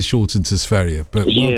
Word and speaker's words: Sverige: No, Sverige is the right, correct pronunciation. Well done, you, Ruby. Yeah, it's Sverige.